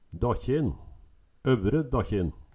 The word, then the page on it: Dakkjin, Øvre Dakkjin: Numedalsmål